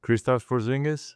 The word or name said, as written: Kristaps Porzingis